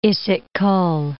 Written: Ysyk-Kol

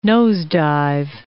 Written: nosedive

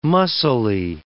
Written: mussily

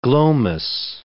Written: glomus

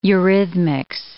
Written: eurythmics

